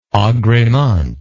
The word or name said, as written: agrement